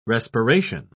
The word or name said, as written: respiration